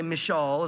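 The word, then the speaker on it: Michal: And it's interesting, when Michael, or however you say it, I think they say Mishal, is how they say it in Israel, Mishal.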